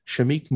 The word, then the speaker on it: Shameik: God, am I fucking saying his name wrong? Jesus, let me make sure. It's Shameik. I don't know if it's Shameik or it is. – Shameik Moore.